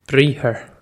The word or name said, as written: briathar